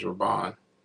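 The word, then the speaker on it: Rabban: Did I say that right? Raban? Or is it Rabin? – I think it's Raban.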